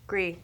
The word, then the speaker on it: Gris: Gris in French. – G-R-I-S. – That's gray in French, but you don't pronounce the S, like Pinot Gris.